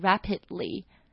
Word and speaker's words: rapid: 我们不会说就是 it's developing really fast, 我们会说 it's developing rapidly, rapidly, 就是迅速的。那大家如果不太会念这个字的话，就把它想 rabbit, 就它的 A 呢是一样的发音，就兔子的那个 rabbit, rapid, rabbit。